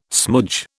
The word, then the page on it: smoodge: British English